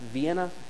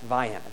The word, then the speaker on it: Vienna: In all other parts of the world, by the way, that word is pronounced Vienna. In South Georgia, it's pronounced Vienna. That is the name of the town there.